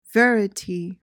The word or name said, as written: verity